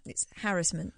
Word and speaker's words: harassment: It rhymes with embarrassment. I don't want to embarrass you, but you are embarrassing yourself. – Okay, well, so I'm going to... Say again? – It's harassment.